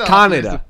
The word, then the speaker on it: Kaneda: And I'm like, Kaneda? Good God, you even pronounce it like an idiot. It's Kaneda. – So the way I said it again, yeah. – It's Kaneda.